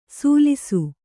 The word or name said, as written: ಸುಲಿಸು